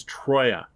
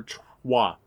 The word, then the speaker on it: Troyes: is Troyes, or Troyes, T-R-O-Y-E-S. I've heard it pronounced both ways.